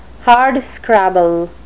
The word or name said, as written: hardscrabble